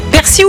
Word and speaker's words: persil: Alors normalement c'est persil, ok, ouais. D'après le dictionnaire de l'Académie française, mais le nord et le sud du pays prononcent quand même le L, donc t'as le droit quand même aux deux.